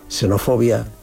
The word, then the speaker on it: xenofobia: Hay palabras que comienzan con X y todas las palabras que comienzan con X sigues escribiéndola con X, naturalmente, pero el sonido es con S. Xenofobia, xeroftalmía, que es la sequedad del ojo, xilófago, que son las termitas, los que comen madera. El sonido X al comienzo de sílaba no es correcto, aunque escribas con X.